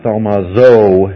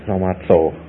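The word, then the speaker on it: thaumatzo (θαυμάζω): And this one, we put the Z in there, but it's not thaumazo, it's thaumatzo, okay?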